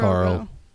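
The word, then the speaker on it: Kahl: It's K-A-H-L. – K-A-Call? – Call. – Sounds like Jarbo. – Carl. Sounds like Carl. Just call him Carl.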